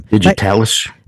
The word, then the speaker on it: digitalis: The deaths ended after the police had been called in and the digitalized type medication, digitalis type, butchering that pronunciation or pronunciation. Sorry if I am. – Digitalis? – Digitalis type medication that had possibly been used for the alleged killings had begun to be kept under lock and key.